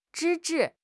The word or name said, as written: 制止